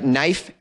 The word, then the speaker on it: knife: Wherever it's silent, there's all these silent letters, like knife, K-N, the K is silent.